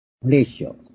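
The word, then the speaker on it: Horatio: なぜこのことに触れるかというと、このフィリップ・ポール・ブリスという人、それからこの 520 番を作った、ホラティオ・スパッフォードという人ですね、作詞者の方。ホレイシオと読むことも思いますが、スパッフォード。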